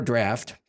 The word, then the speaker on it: draught: Even while quaffing the third draught of the Fountain of Youth, or draft, probably. – I said it wrong.